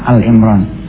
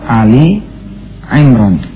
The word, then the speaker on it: Ali Imran: Nih, pemirsa suka salah baca nih. Biasanya suka bacanya Al Imran. Padahal bukan Al Imran tapi Ali Imran, ya.